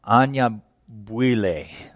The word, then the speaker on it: Anyabwile: I appreciate what Tabiti, I always say his last name wrong, it's Anyabwile.